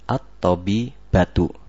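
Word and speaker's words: At-tabibatu (الطَّبِيبَةُ): At-tabibatu. Jangan dipanjangkan ba-nya. Kalau dipanjangkan, nanti orang yang dengar mengira bahwa dokternya itu jumlahnya lebih dari dua.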